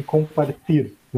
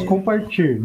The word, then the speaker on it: compartir: Então, é, é, os espanhóis, os, os espanhóis, né? Os venezuelanos dizem compartir né? – Ah, nós okay. falamos compartir, né? É assim que nós pronunciamos o T.